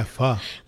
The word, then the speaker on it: pho: And then if I want something hot, I'll go for a bowl of pho. Is that how you pronounce it? – Yeah, pho.